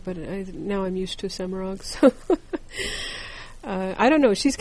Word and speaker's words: Semirhage: She may be one of the most mispronounced names in Wheel of Time. It is not Semirhag or Semirhage, Semirag.